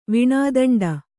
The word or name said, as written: ವೇಣುದಂಡ